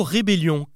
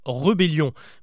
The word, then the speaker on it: rébellion: Enfin, une dernière erreur qui là pour le coup relève plus de l'inattention, c'est le mot « rébellion » que beaucoup de monde prononce « rebellion ». Mais si vous le lisez, vous constaterez qu'il y a bien un « e » accent aigu au début.